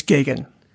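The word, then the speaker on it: Gaghan: I said this wrong last week, too. What is it, Grierson? – It's Gagan.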